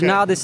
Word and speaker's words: Naadhe: So it's called Project Nade. – Spell that, please. – It's Project, like P-R-O-J. – Project? – Yeah, Project, and N-A-A-D-H-E. – Okay. – It's Nade. So Nade means sound, right?